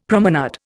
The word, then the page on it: promenade: Indian